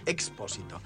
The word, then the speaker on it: Expósito: Homero Manzi, Expósito, no pronuncié mal la X.